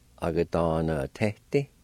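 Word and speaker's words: agadanvtedi: A-ga-da-na-te-ti.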